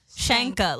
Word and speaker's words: Shanka: Shanka? Shanka. Like you're going to shank a bitch.